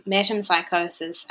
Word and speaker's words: metempsychosis: I believe that is how you say it, metempsychosis.